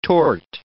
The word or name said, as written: tort